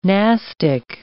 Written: nastic

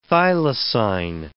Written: thylacine

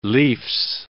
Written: leafs

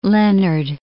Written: Leonard

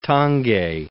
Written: Tange

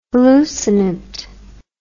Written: hallucinant

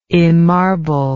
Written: emmarble

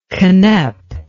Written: ginep

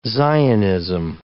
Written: Zionism

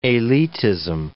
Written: elitism

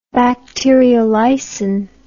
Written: bacteriolysin

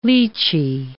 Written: lychee

